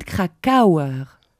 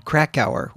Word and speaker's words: Krakauer: Parce que moi, j'ai toujours dit David Krakauer. Et en écoutant, en entendant, c'est David Krakauer. – Oui, en anglais, David Krakauer, oui.